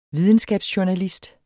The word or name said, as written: videnskabsjournalist